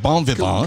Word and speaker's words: bon vivant: The T would not be pronounced in French. Bon vivant.